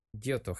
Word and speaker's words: Giotto: Yoto, G-I-O-T-T-O, con dos T's.